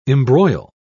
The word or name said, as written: embroil